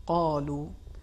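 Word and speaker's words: قالوا: حرف قاف کے بارے میں پچھلے پروگرام میں میں نے عرض کیا کہ اس میں صفت تفخیم پائی جاتی ہے، لہذا صحیح تلفظ قالو، سورہ عنکبوت کی تلاوت میں آپ نے دیکھا کہ انہوں نے حرف قاف کو تھوڑا کاف کے جیسا تلفظ کیا، اس پر توجہ رکھنی چاہیے.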